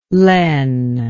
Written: len

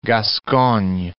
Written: Gascogne

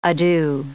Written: ado

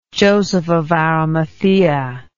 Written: joseph of arimathaea

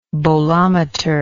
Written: bolometry

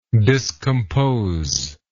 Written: discomposingly

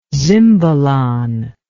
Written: zimbalon

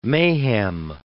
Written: mayhem

